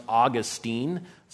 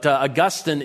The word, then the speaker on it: Augustine: All right, I want to start with Augustine, or sometimes it's pronounced Augustine, especially if you're from Florida. But Augustine is a more correct pronunciation of his name, though either one is fine.